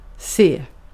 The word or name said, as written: se